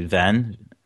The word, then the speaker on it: Ven: I just say Ven. – All right. – And usually they misspell it as Ben, like instead of V-E-N, but, you know, it works.